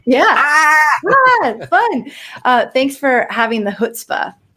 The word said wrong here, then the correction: chutzpah: No, you got to sound like you're ready to cough something up. It's chutzpah.